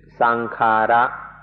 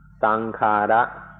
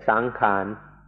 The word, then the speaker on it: sankhara: the Pali word, which is sankhara, sankhara. Thais make it a little easier to pronounce and say sankhan, sankhan. But in English books, it will be spelled out sankhara.